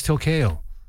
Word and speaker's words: tilcayo: And they've given it the name Leopardus tilkeo. – I love when you have to say hard words. – It's not a hard word to say. Leopardus tilkeo. – Tilkeo? – Yeah. T-I-L-C-A-Y-O.